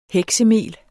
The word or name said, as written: heksemel